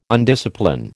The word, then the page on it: undiscipline: American English